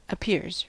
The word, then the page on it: appears: American English